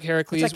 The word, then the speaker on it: Heracles: Project Heracles, which was funded by M. – I kept thinking that they were mispronouncing Hercules, but actually it's spelled differently.